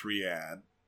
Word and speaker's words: Riyadh: And, oh lord, and just in case anyone was annoyed, yes, we know, it's pronounced Riyadh.